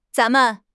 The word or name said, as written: zan2 men5